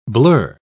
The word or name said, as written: blur